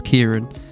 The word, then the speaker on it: Ciaran: And I found this opinion piece by, I'm assuming how you say it, it's Kieran, C-I-A-R-A-N, Thapar, and I found it very interesting read.